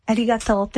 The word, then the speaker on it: Arigato: Arigato. Yeah. – Arigato.